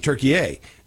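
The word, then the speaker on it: Türkiye: Now we had the World Cup here, and they started saying Turkey-A, which is, I guess, how they pronounce it there.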